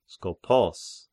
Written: Skopos

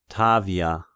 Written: Tavia

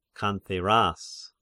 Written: Cantheras

